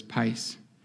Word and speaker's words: pais: That word, which we would pronounce in, which is pronounced as pais, it has one very common meaning.